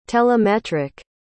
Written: telemetric

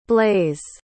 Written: blayze

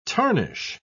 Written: tarnish

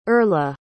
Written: Erla

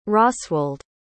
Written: Rosswald

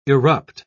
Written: irrupt

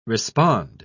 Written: respond

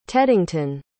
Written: teddington

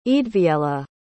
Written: eadwiella

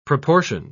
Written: proportioned

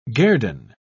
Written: guerdon

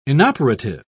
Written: inoperative

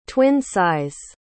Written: twin-size

